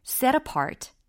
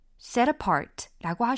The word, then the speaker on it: set apart: 예, t를 내느냐 안 내느냐 요 차이도 느끼셔야 합니다. I always, I always 이렇게 빨리 발음하실 수 있으면 좋아요. I always, I always 그다음 set apart, set apart라고 하셔도 되고 set apart, set apart 이렇게 하셔도 돼요.